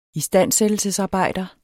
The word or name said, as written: istandsættelsesarbejder